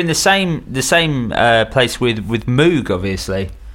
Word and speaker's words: Moog: Moog was supposed to rhyme with Vogue. That was where it came from. He was like, that's how you're supposed to pronounce it. – But the Mooga Fooga doesn't work as the Mooga Fooga. – Yeah, I know, but that's how they pronounce it. That's how he pronounced his own name.